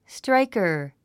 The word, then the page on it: striker: stráikər; ストライカァ